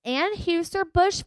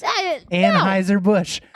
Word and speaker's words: Anheuser-Busch: It's German. The EU makes I. Like Neumann microphones. Anheuser-Busch. – I don't know. No. Never heard of any of that. – So Anheuser-Busch is Budweiser.